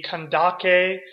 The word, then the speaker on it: Kandake: And that term Candace, or really the pronunciation would be Kandake, isn't so much a name as it is a title.